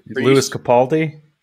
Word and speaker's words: Lewis Capaldi: Lewis Capaldi.